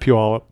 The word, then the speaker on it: Puyallup: I'm from Puyallup. Is that how you pronounce that? – Puyallup.